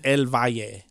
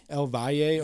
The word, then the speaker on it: El Valle: Like I said, how do you pronounce it? It's El Valle, right? That's how you say it. – El Valle. – El Valle.